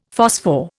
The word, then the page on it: phosphor: Indian